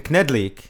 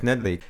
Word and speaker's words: knedlík: Knedlik or Jiaozi? – All right, that's actually pretty good pronunciation, knedlík. That's right. I have to go with knedlík.